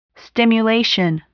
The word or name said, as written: stimulation